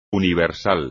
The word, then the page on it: universal: espagnol